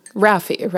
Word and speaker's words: Rafi: How is it pronounced in the audiobook? Rafi, right? I'm pretty sure Rafi. – Yeah, like the, you know, the guy. – Like the Canadian children's singer, yeah.